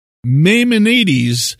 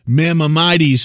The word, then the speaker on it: Maimonides: That is what Maimonides said, or Maimonides. Maimonides. It's spelled this way, just in case you want to look it up. Maimonides. Maimonides, or almost like mammon.